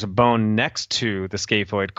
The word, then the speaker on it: scaphoid: And how do you pronounce that word? – Yeah, it's scaphoid.